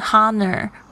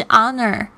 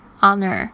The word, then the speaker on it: honor: 因为这 honor 其实呢，这个 h 是没有发音的哦，不要念成 honor 会很好笑，是 honor honor。